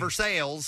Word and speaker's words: Versailles: Versailles. – No, but I love that. It's like Versailles, but we call it Versailles.